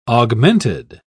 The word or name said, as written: augmented